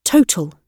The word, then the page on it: total: British English